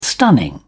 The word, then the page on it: stunning: British English